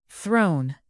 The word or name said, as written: throne